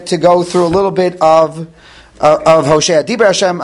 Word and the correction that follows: Dvar Hashem: I'm sorry, Dvar Hashem, I have the pasuk right in front of me. I should be able to pronounce it correctly.